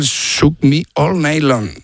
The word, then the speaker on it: Shook Me All Night Long: Shoot Me All Night Long. – Ah, sí. Lo has dicho en francés. – Has cambiado cuatro palabras de sitio. – Has engolado tanto que se te ha pasado de idioma. – Qué grande, tío. Qué grande. – Me cuesta el Shook Me All Night Long. – Ahí mucho mejor.